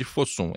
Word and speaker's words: Fosun: É chinês, então a gente não sabe é se deve pronunciar meio inglês ou meio chinês. Vamos de Fosun, F-O-S-U-N.